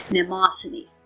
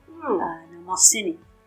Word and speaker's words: Mnemosyne: And I believe you pronounce this in Greek, Mnemosyne. Mnemosyne.